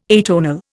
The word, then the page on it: atonal: Indian